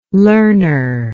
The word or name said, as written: learner